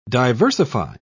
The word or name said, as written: diversify